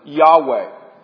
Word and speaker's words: Yahweh: This is where we get the name Jehovah from. Some pronounce it Yahweh.